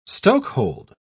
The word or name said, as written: stokehold